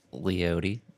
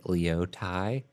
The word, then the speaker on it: Leote: Well, the guy from KOSU always pronounces it Leotai, which... I mean, it's a traditional Native American name, so that might be the actual pronunciation, but Leote just fits the vibe of music, and it's goofy. – It's a little bit more chill. – Yeah. Plus, it's more comfortable just saying Leote than Leotai.